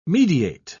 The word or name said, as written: mediate